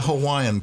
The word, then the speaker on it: Hawaii: You got to do that break now because you spell it Hawaii. – That's right. – You know, you just don't glide through it.